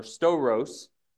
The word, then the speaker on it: stauros: The Greek word translated cross in English is the Greek word stauros or storos. It's spelled S-T-A-U-R-O-S. We'll look at two Greek words today that are closely related. Again, S-T-A-U-R-O-S. Storos is how it's pronounced.